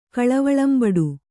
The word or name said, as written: ಕಳವಳಂಬಡು